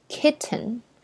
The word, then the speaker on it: kitten: 叫做 kitten。是吧？Kitten，这个涉及到我们以前学的一个发音方式，就是这个 t 后面如果加 n 的话，那这个 t 是会要 hold 住的。